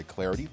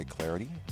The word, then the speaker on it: Clardy: Number one, you can follow me on Twitter at Troy Clarity. Last name is spelled C-L-A-R-D-Y, at Troy Clarity.